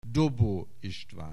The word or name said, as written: Dobó István